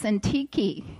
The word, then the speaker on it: Syntyche: I practiced this, Syntyche. It is actually pronounced Syntyche.